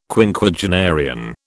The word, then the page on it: Quinquagenarian: British English